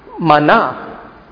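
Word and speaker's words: mana (מן): And if you want to pronounce it, the best way I can think of pronouncing it in Hebrew is the mana. Mana, right? Ma is what? Na is this.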